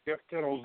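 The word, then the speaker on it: Tittel: Did I pronounce his last name right? Is Jeff Tittle? – Yeah, Jeff Tittle's director of the New Jersey Sierra Club.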